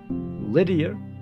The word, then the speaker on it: Lydia: Silver and gold coins have been used as currency for centuries, dating back to around 600 BC in ancient Greece, in Lydia, Lydia, L-Y-D-I-A. You say potato, I say potato. Lydia. L-Y-D-I-A. So Greece for sure, and Lydia, deal with it. My pronunciation, all due respect.